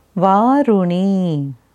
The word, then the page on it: Varunee: Sanskrit